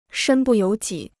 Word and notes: 身不由己: shēn bù yóu jǐ

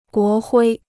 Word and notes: 国徽: guó huī

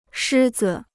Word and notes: 狮子: shī zi

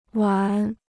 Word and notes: 完: wán

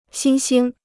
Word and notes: 新兴: xīn xīng

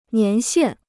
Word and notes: 年限: nián xiàn

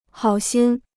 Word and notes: 好心: hǎo xīn

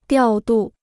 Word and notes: 调度: diào dù

